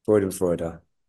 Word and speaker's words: Freudenfreude: What's that term with pop pop pop, the Freudenfreud? Am I saying that right? – Freudenfreude.